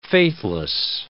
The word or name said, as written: faithless